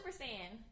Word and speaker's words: Saiyan: Super Saiyan.